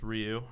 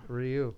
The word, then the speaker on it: Ryu: Is it Ryu or Ryu? – It's Ryu. – Ryu. – We always said it wrong growing up.